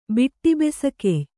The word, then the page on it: ಬಿಟ್ಟಿಬೆಸಕೆಯ್: kannada